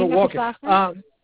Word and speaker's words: Walken: Next paragraph, there are certain things in life that no matter how many times, that paragraph I'm looking at, and last line, Christopher Walken, how do you pronounce that, and is that correct? – Yeah, Christopher Walken.